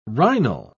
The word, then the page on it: rhinal: British English